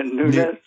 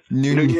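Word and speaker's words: Núñez: It, it, uh, Nunez, Nunez, Nunez, Nunez. You have trouble with the yeah, N with the little thing over it.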